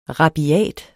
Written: rabiat

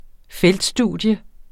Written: feltstudium

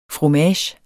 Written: fromage

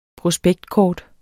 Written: PROSPEKTKORTENES

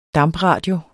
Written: DAMPRADIOERNE

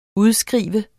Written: udskrive

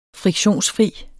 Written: friktionsfri

